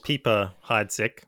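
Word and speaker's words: Piper-Heidsieck: So I think officially it's called Pieper Heidsieck. Well, that's how you'd pronounce pipe or peep in French.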